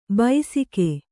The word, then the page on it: ಬೈಸಿಕೆ: baisike; kannada